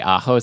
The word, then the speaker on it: ajo: I think you mean Mojo. – Okay, yes. It's Ajo. – Mojo to Ajo.